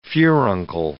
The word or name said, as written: furuncle